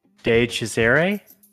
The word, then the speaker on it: DiCesare: De Cesare, do you want to correct me? – Nick DeCesare.